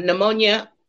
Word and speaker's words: pneumonia: I had pneumonia.